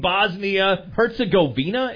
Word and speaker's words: Bosnia Herzegovina: So, they were playing Bosnia Herzegovina.